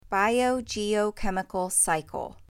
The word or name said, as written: Biogeochemical cycle